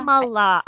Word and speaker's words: Kamala: So, and speaking of gloom and doom, um, Kamala, did I say that right? Cam- Kamala. Kamala.